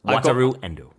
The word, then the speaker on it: Wataru Endo: Wataru Endo.